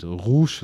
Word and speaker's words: Rouge: Und ja, diese Sequenzen mit Rouge, so spricht man den aus, nicht zu verwechseln mit Leo Rush, aber man schreibt sie beide gleich, aber den einen sprichst du Rush, den anderen sprichst du Rouge.